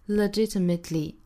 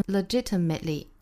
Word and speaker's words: legitimately: 叫做舌边爆破。那一般的话呢，会把它发成一个 t 的发音 legitimately, legitimately。那如果说大家感觉这个音实在不好发呢？也可以选择把这个 t 的音省掉，发成 legitimately, legitimately, 这样一带而过也是可以的。